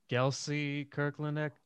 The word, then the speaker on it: Gelsey Kirkland: So it was, I might say this wrong, but Gelsey Kirkland Academy? – Yeah, that was great. You said it just right.